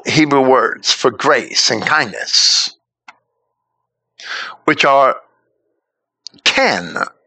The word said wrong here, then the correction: chen: I should probably pronounce that with a hard CH, ken or chen, and chesed or chesed, are quite close in spelling, and the respective roots are very similar in meaning.